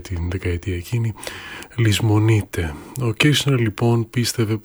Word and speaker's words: Kirchner: Ο συνθέτης, ο Kirchner ή Kirchner, στην Αμερικάνικη προφορά, ο ίδιος πίστευε πως η αμιγώς ηλεκτρονική μουσική οδηγεί τον ακροατή τελικά στην ανία.